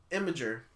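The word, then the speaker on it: Imgur: Imager. Did I say it right? – Yes.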